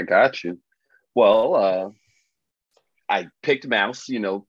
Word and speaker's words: Maus: It's pronounced Mouse, right? Or Moss. I've been calling Mouse, yeah. – I always assume Mouse. – M-A-U-S, yeah.